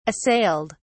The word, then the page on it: assailed: British English